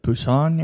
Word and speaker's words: Busan: While a zombie virus breaks out in South Korea, passengers struggle to survive on the train from Seoul to Busan, I think is how you say it. – Busan, yeah.